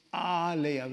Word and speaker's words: alaya: Second is alaya, with a long A, not short A. Alaya means house, house of snow. That's what Himalaya means. Children, you learned something new today, right? Because you came to church. When you make it Himalaya, meaning changes. Because short A, alaya means ocean.